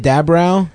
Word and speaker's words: DeAbreu: How do you say it? DeAbreu? – DeAbreu.